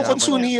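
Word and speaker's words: Consunji: Konsunhi. – Mayaman yan, mayaman. Kasi may silent letter.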